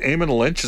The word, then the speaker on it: Eamon Lynch: Did you see the one from, is it Eamon Lynch? Is that how it's pronounced? – Yes. Yeah.